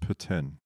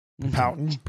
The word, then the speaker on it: poutine: It's pronounced poutine. – Poutine.